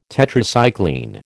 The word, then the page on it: Tetracycline: American English